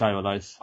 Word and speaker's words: Ivalice: A recurring Moogle in Ivalice, is that how you pronounce it? – Final Fantasy thing? Yeah, it's Ivalice.